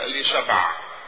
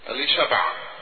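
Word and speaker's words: أليشبع: ذات نفس هارون الكاهن اللي هو رئيس الكهنة واولهم، زوجته برضو كان اسمها اليصابات، بس كان تتنطق باللغة العبرية الاصيلة اللي هي اليشبع، اليصابات بالنطق اليوناني اليشبع.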